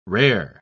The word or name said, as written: rare